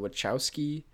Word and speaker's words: Wachowski: Directed by the, how do you pronounce their last name? The Wachowski. – I think so.